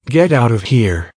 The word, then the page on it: get out of here: American English